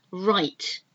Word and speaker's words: write: W-H-O-O-L-E. W-H-O-L-E. Whole. So again, you don't pronounce the W. Write. I'm going to write something down now. And this write is W-R-I-T-E. Pronounced exactly the same as right, which is not wrong, it's right.